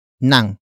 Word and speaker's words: nang: The next word is nang, which is spelled as ng.